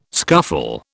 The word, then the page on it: scuffle: British English